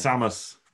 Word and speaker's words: Samus: It's Samus.